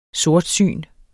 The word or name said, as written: sortsyn